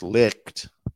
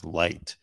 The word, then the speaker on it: Licht: Anyways, Chris Licht, or I think it's pronounced Light, branded Don Lemon's comments as unacceptable during a 9 a.m.